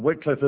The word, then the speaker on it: Wycliffe: And Wycliffe is the right way to pronounce his name.